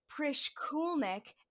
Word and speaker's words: Prishkulnik: Prishkulnik. Emphasis on the cool.